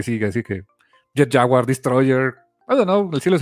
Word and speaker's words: Destroyer: Y que mató a Godzilla, es Destroyer, así se pronuncia y así está escrito.